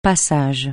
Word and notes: passage: francais

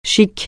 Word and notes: chic: français

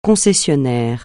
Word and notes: concessionnaire: francais

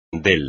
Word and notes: del: espagnol

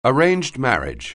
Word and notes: arranged marriage: anglais

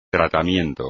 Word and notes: tratamiento: espagnol

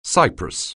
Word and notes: cypress: anglais

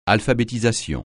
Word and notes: alphabétisation: francais

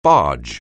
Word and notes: barge: anglais

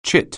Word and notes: chit: anglais

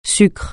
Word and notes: sucre: francais

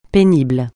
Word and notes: pénible: francais

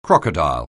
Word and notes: crocodile: anglais

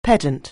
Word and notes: pedant: anglais

